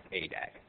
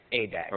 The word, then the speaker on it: ADAAG: With respect to the ADAG, the ADAAG is pronounced ADAG. – ADAG.